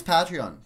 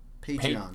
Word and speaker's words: Patreon: I think it's Patreon. – Yeah, like patronizing. Patreon.